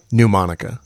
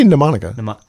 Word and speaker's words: mnemonica: How does he say it? – Mnemonica. Like it was spelled P-N-E-U-M-O-N-I. – I'm asking this as a true friend. How do I say it? I'm trying to... – I think you say mnemonica.